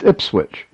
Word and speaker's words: Ipswich: Excellent answer, and I want to thank Adam for also letting me know that I correctly pronounced Ipswich.